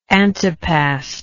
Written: antepast

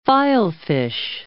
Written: filefish

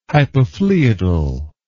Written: hypophloeodal

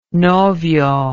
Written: novio

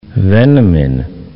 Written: Veneman

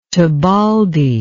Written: tebaldi

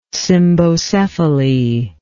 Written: cymbocephalous

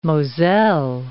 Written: Moselle

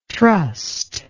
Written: trussed

